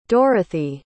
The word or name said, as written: Dorathy